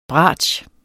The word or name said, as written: BRATSCHEN